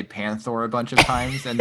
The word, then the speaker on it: Panthor: Because you keep saying Panthar, and I always thought it was Panthor. – Oh, I might just be wrong. – You can't even Google that. How do you pronounce Panthor? – Yeah. How's it spelled? – P-A-N-T-H-O-R is Panthor.